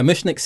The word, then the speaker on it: Mushnik: To be fair, I think I was on Mushnik's side. Is that how I pronounce it? – Yeah.